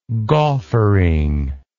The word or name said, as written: gauffering